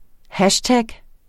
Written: hashtag

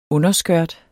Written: underskørt

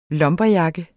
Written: LUMBERJACKETS

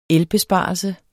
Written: elbesparelse

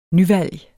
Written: nyvalg

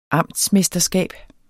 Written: amtsmesterskab

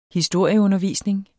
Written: historieundervisning

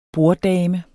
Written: borddame

